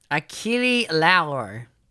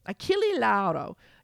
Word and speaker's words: Achille Lauro: Achille Lauro. – Achille Lauro.